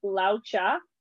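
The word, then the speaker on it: Lao Tzu: So the pronunciation is Lao Cha, mm-hmm. and it's a Chinese pronunciation.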